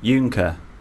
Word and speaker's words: Juncker: Our producer is trying to tell us how to pronounce Jungkyu, but she's put Y-O-O dash N-K-E-R. – Juncker.